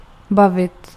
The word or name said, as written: converse